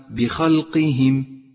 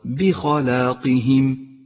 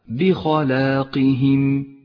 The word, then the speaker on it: بخلاقهم: لونت هذه الألف الخنجرية باللون الكموني إشارة إلى مدها حركتين، فإذا أهملتها من اللفظ صارت الكلمة بخلقهم، ولكنك إذا نطقتها صارت بخلاقهم وهو الصواب. بعدها حرف القاف مفخم وحرف الهاء والميم حرفان مرققان، فاقرأ هذه الكلمة بشكل صحيح هكذا بخلاقهم.